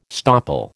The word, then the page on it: stopple: American English